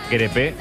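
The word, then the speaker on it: Crépeau: Dice Martínez que se dice crepe, como un crepe de Nutella, de nocilla.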